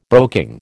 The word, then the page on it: broking: American English